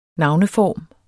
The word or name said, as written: navneform